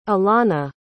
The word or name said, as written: elana